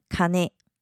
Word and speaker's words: かね: 接下来是金这个字，金可以念成卡内，卡内，卡内，也可以念成 king，king，king。